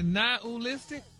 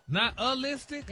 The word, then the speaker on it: nihilistic: But you actually pronounced it correctly the last time you said it. – Really? Nihilistic? – Wow. – Stop putting the ooh in there. – Nihilistic? – There you go.